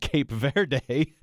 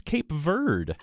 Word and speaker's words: Cape Verde: It turns out, and I learned this from the World Cup, which has been pretty exciting, but I learned that Cape Verde is not Cape Verde. Did you know this, how it's pronounced? – Go on. – It turns out it's Cape Verde.